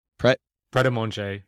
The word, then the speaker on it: Pret A Manger: The Pret A Manger, how do you say that? Pret A Manger? – Pret A Manger.